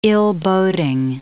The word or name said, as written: ill-boding